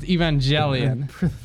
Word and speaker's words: Evangelion: I think it's pronounced Evangelion because it's jelly.